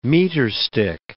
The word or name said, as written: meterstick